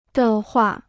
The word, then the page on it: 的话: de huà